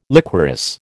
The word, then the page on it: liquorice: American English